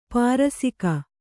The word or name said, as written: ಪರಿಸೇಕ